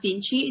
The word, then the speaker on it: Vinci: I've got Alanah Vinci.